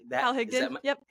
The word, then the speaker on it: Hal Higdon: Hal Higdon.